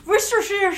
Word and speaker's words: Worcestershire: I don't even know if I'm saying it right. – It's Worcestershire sauce. Worcestershire sauce.